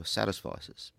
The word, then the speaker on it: satisficer: And in particular, you draw on this distinction in the literature between people who are maximizers and those who are satisficers.